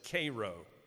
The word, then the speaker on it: Cairo: It's spelled like and looks like Cairo, Egypt, but in Georgia they pronounce it Cairo.